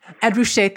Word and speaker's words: Ed Ruscha: Ed Ruscha, thank you.